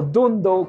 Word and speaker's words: Dhandho: Así que nada, esto era Club de Lectura de Dando o Dundo, como se pronuncia, Investor de Monish Pabrai.